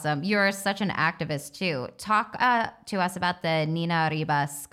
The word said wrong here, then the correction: Niñas Arriba: College Fund. Did I say that right? – Yeah, Niñas Arriba.